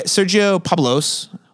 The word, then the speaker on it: Sergio Pablos: It is directed by Sergio Pablos.